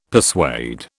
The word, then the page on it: persuade: British English